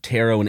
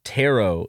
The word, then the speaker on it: tarot: In any case, the T in tarot is never pronounced. In British English, tarot is pronounced as taro, while in American English, tarot is pronounced as taro.